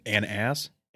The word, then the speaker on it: Anas: Don't know how to pronounce it. – Anas. – A-N-A-S.